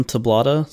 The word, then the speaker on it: Tablada: That's Ryan Tablada. So it's RyanTablada. I know my name's a little bit weird. Sounds just like it's spelt, but people get it all messed up.